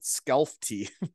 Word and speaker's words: Skellefteå: I always called it Skellefteå.